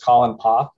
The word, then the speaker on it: Colin Poff: And my name is Colin Poff. It's spelled C-O-L-I-N-P-O-F-F.